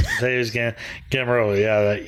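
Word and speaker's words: Mateusz Gamrot: Armand Sarukian against Mateus Gamrot. Yeah, that's how you say it.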